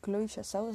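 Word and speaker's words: Chloé Zhao: Eh, la directora es Chloe, Chloe Chloe Chassau, así se pronuncia.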